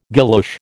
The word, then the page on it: guilloche: American English